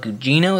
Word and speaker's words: Gugino: Mike Flanagan's Fall of the House of Usher coming to Netflix adds 5 people to its cast, including Carla Gugino.